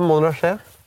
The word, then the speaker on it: Montrachet: Du riktig uttale. Det Montrachet.